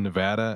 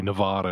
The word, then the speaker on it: Nevada: Yeah, we started talking about pronunciations, and we both live in Nevada, and we're saying, it's like, oh, yeah, a lot of people say Nevada or whatever, but yeah, the people in the know saying, oh, yeah, but, you know, technically, if you're trying to say it like the Spanish origin, Nevada, then that's okay.